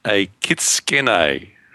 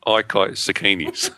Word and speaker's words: Ikitsukene: It is spelt IKITSUKENE and pronounced a Kitskene, not Ikitsukenes.